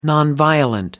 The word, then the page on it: nonviolent: British English